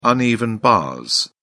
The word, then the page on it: uneven bars: British English